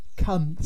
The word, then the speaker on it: Cunth: Cunth. – C-U-N-T-H, is that? – Yes. Spelled the same as the naughty word, but with a H.